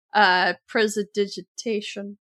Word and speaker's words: Prestidigitation: It's just like chill touch, Eldritch Blast, Prestidigitation, Chaos Spray.